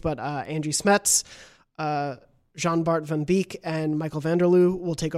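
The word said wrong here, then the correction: Jan-Bart van Beek: I think it's Jan. Uh, Jan Bart yeah. Van Beek.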